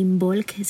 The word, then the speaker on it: Imbolc: Entonces, si esto te movió el corazón, pues por ahí puedes buscar información de Imbolc. Se escribe I latina, M, B de bueno, O, L, C, Imbolc. Así se dice.